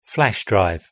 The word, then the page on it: flash drive: British English